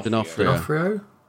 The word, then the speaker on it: D'Onofrio: D'Onofrio.